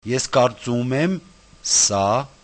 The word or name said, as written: eskartsumemsa